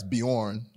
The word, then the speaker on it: Bjorn: All right, so you gotta pronounce it right. It's Bjorn. – Bjorn. – It's a Swedish name, even though I'm not Swedish.